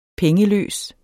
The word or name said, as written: pengeløs